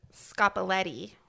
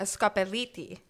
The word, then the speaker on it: Scopelliti: Well, what's commonly referred to as a scopoletti is actually pronounced a scopelliti application. – Or scopelliti. – Scopelliti, yes, but not a scopoletti.